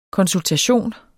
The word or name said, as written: konsultation